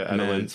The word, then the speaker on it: Adelaide: Adelaide man, is that how you say it? Adelaide? – Yeah, Adelaide.